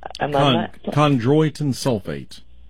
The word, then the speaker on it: Chondroitin sulfate: Last week, you started off with a study about chondroids and sulfurite. – Chondroitin sulfate.